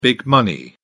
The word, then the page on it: big money: British English